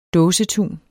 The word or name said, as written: dåsetun